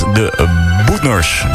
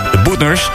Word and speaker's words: De Boetners: De Boetners. Zo moet je het uitspreken. De Boetners.